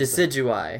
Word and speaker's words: Decidueye: How do you pronounce Decidueye. – It? – Decidueye.